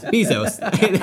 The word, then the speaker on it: Bezos: Jeff Bustos can go suck a dick. Bustos? Bustos? – Bezos? – I don't know how to pronounce any names. Bezos. There we go.